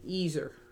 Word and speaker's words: ezer: That word there that says help is ezer.